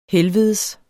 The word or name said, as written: helvedes